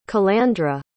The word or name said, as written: calandra